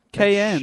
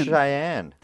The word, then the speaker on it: Cheyenne: Cayenne. – Cheyenne.